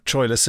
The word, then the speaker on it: Troilus: I went to see a production of Troilus and Cressida at the National years and years ago, and they decided to use what was apparently the correct pronunciation, which is Troilus, not Troilus.